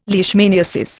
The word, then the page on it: leishmaniasis: Indian